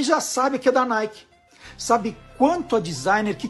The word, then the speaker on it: Nike: Se escreve Nike, mas se lê Nike.